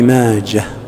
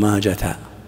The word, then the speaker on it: ماجه: قال: ولابن ماجه، ابن ماجه الإمام المشهور صاحب السنن رحمه الله، وماجه والده يزيد، وعند المحدثين الأوائل لا تنطق هذه الكلمة بالتاء حتى في الوصل، لا تقول رواه ابن ماجة، وجوز جمع من المحدثين لا سيما في هذا العصر أن تنطق، لأنها أصبحت كالعلم.